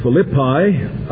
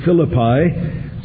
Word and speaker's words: Philippi: The correct pronunciation is Philippi. I've been accustomed to saying for years Philippi,